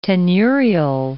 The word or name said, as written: tenurial